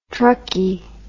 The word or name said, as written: truckie